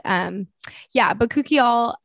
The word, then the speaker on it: Bakuchiol: Bakuchiol, and then I love their body polish scrub.